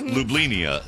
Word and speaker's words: Ljubljana: That's how it's pronounced, Ljubljana, Slovenia.